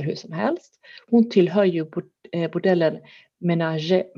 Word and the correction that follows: Menagerie: Menager kanske det uttalas? – Menagerie. – Menagerie till och med, tack så mycket. Min franska är lite sisådär, men menagerie, tack så mycket.